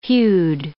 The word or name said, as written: hued